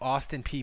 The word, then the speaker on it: Austin Peay: Explain to me how the letters P-E-A-Y pronounce P? – I don't know. – I thought it was P-A. I thought it was Austin P-A.